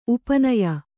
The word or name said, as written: ಉಪನಯ